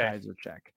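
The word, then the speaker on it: Księżarczyk: K-S-I-E-S-Z. A-R... C-Z-Y-K. – You are so close, but no, you gave him one extra S. It is K-S-I-E-Z-A-R-C-Z-Y-K. That is okay. – Kaiserchek.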